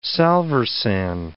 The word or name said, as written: salvarsan